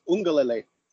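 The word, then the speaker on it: Uiagalelei: Ugiangale. No, I butchered it. – It's Ungalele.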